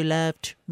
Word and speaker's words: Loved: Aujourd'hui, bien, j'ai choisi une chanson, un de mes succès, un de mes nombreux succès, Because You Loved Me. Les gens ne prononcent pas le D, mais c'est ça se passe avant. Fait que c'est pour ça qu'il faut mettre le D.